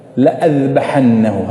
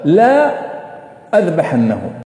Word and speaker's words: لأذبحنه: ها يا شيخنا، لام ألف وألف أذبحنه، يعني لو نقرأها كما هي مكتوبة لا أذبحنه، يعني بالعكس. والآية تقول لأذبحنه، لكن مكتوبة ألف وعليها علامة سكون، صح؟ يعني لا تنطق.